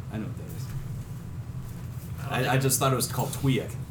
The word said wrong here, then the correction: Twi'lek: I've heard it called Twi'lek. – You were mispronouncing something? – I guess I was.